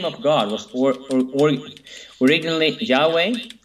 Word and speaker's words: Yahweh: Yeah, Yahweh is the correct pronunciation, yeah.